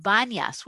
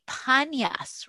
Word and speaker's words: Banyas: There is a site called Banyas, which is really Panyas, right? Remember, Arabs cannot pronounce the letter P. So anything that is a P, we see this in our sources. It actually talks about Panyas. It got corrupted into the name Banyas.